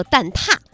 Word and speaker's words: 蛋挞: 就是你们说的那蛋挞，它的标准读音叫做蛋挞，没想到吧？ – 哎，对，它叫蛋挞，我们是怕监听了而看听了啊。标准读音叫蛋挞啊，就是你们这两天我看很多朋友都吃到了那个超长的蛋挞， 60厘米，排队两三个小时， 16.8 一根，每个人限购 5个，代购价炒到了 56.8。